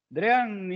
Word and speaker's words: Drean: Drean is, we pronounce here Drean. Because I don't know what it means, because it's not Dream, it's Drean with an N.